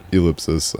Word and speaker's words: ell!psis: I say it, Ellipsis. I know it looks... I've gotten some pretty surprising butchers on Ellipsis over the years.